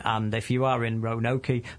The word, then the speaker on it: Roanoke: R O A N O K E. That's Roanoke, isn't it?